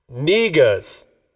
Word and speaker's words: Negus: Niggas. N-E-G-U-S.